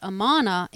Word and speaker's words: Amun: So, but the connection to Hidden One is the name Amun, written I-M-N, pronounced Amana in ancient Egyptian, meant something like the hidden one or invisible.